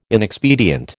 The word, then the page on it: inexpedient: American English